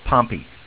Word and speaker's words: Pompey: And I have a slight advantage in that name, because there is a town just south of here, which is spelled Pompeii, P-O-M-P-E-Y, but everybody calls Pompey around here.